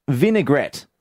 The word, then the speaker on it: vinaigrette: Let Matt know that it is pronounced vinaigrette.